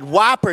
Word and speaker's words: Whopper: Because, first of all, it's pronounced Whopper Dad, Whopper, it's not Whooper.